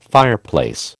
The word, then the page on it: fireplace: American English